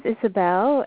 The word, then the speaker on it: Isobelle: Yeah, my name is Isabel. It's spelled I-S-O-B-E-L-L-E, Kate, C-A-T-E.